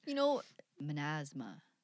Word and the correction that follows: Mazama: mazama. There's no N there. You just like threw an N at the before the Z there.